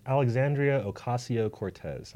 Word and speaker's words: Alexandria Ocasio-Cortez: The congresswoman's name is Alexandria Ocasio-Cortez, and I believe I pronounced that correctly.